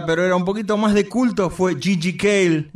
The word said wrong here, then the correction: J.J. Cale: J.J. Cale, en realidad se dice así, que murió hace un tiempito también, ¿eh?